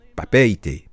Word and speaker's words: Papeete: Papeete, c'est ça, en Tahiti, les amis.